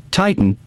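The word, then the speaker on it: titin: This enabled the microbes to produce the high molecular weight muscle protein, Titan, which was then spun into fibers. – Titan, like Clash of the Titan.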